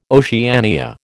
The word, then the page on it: Oceania: American English